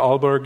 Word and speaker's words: Aalborg: You also hold positions in Denmark, including visiting researcher at Aalborg University. Did I pronounce that correctly? – That's correct.